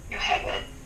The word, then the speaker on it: Yocheved: Is it true that you guys designed Yoheved or Yocheved? How do you pronounce that? – Yoheved. – Yoheved.